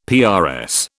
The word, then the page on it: PRS: British English